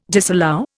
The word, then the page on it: disallow: Indian